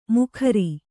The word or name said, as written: ಮುಖಾರಿ